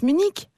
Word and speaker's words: Munich: Quand même, vous devriez savoir que le nom de cette ville s'écrit Munich, c'est vrai, mais se prononce Munich.